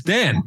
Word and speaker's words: Dan: It's like, my name is not Daniele or whatever. It's Dan.